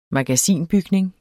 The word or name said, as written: MAGASINETS